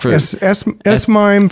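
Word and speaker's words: S/MIME: And I think S-MIME, is that how you pronounce that? – S-MIME for digitally signing emails.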